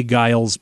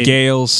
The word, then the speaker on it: Giles: Did I mispronounce the name of this band? – You did. It's the Jay Giles Band. – I said Gales.